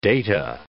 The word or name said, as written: data